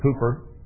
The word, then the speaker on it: Cowper: And Bill Cooper, his name is spelled C-O-W-P-E-R, but the English pronounce it Cooper, William Cooper.